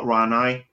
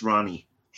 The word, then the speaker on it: Rani: It's not Ranai. It's Ronnie.